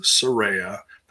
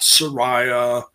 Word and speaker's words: Saraya: And by the way, that is how you pronounce her name is Saraya, not Soraya, not whatever. I don't know. They said a bunch of different things, but it's Saraya.